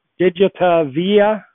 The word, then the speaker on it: Digitavia: The sales and marketing director for Digita Via. Did I say that right, or should I let you correct? Yes, Digitavia, correct. – Digitavia, that's better.